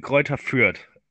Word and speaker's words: Greuther Fürth: But we've got Sandhausen and Greta Fürth. – It's Sandhausen and Greuther Fürth.